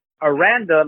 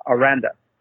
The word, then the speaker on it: Aranda: And Dave Aranda, like you like to call him, is not pronounced Aranda. It's Aranda. He is descendant of Mexican descent. It's Aranda. Thank you.